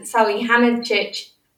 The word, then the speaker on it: Salihamidzic: So he's kind of, with, and everyone knows that since the German national team has come up, him and Salihamidzic,